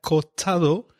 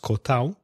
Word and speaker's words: cortado: This feature of dropping sounds, missing sounds, is similar in Spanish with words like cortado, which is sometimes pronounced cortao, and cuñado, which is sometimes pronounced cuñao.